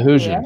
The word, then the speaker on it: Hoosiers: Isn't Indiana the Hoosiers? Did I say that right? – The Hoosiers.